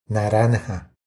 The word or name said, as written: Naranja